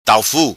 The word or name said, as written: Taufu